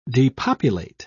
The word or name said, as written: depopulate